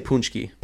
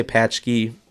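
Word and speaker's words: pączki: What's the story behind the punchki or is it punchki? How to say it? – We say punchki. – Punchki. – Yeah, some people say patchki.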